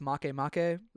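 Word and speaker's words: Makemake: One, I think it's pronounced Make Make. – Make Make. – Oh. – It's M-A-K-E, M-A-K-E.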